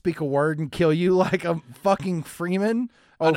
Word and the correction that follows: Fremen: It's Fremen. Fremen. I actually gave you the pronunciation of Fremen.